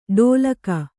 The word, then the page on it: ಢೋಲಕೆ: ḍhōlake